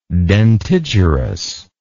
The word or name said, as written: dentigerous